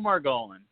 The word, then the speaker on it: Margolin: Joe Margolin.